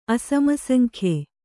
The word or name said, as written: ಅಸಮಸಂಖ್ಯೆ